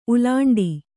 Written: ಉಲ್ಲುಂಠೆ

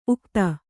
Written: ಉಕ್ಥ

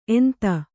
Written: ಎಂತ

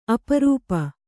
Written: ಅಪರೂಪ

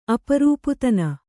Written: ಅಪರೂಪದಿನ

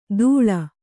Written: ಧೂಳ್

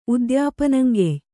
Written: ಉದ್ಯಾಪನಂಗೆಯ್

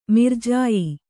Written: ಮಿರ್ಜಾಯಿ

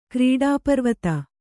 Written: ಕ್ರೀಡಾಪರ್ವತ

